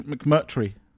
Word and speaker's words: McMurtry: McMurty? – McMurtry. – Oh, is it? Oh, I didn't know that's how it's spelled. – It's got another R in there.